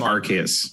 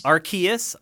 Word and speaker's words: Arceus: I think it's Arceus. – Arceus, the hard C? – I think it's a hard C.